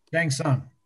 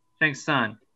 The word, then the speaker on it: Shang Tsung: Shang Tsung. – Shang Tsung.